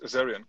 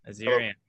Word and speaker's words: Azerion: Today, we have with us from, am I going to say it right, Azerion? – Yes, Azerion, correct. – Azerion.